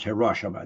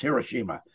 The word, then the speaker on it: Hiroshima: And he's the one who designed the triggers for our bomb that we dropped on Hiroshima, which is not pronounced Hiroshima, it's Hiroshima.